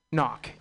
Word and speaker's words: Ngok: It's spelled N-G-O-K. Knock, yeah, Chris Knock, and then non-governmental organization.